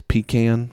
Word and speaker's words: pecan: It's pecan. I'm a little bit of a stickler on pronunciation. People try to say it's pecan or something because that's like a cute southern thing. It's just, well, that's not what, objectively, that's not how the word is pronounced.